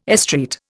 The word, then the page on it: estreat: Indian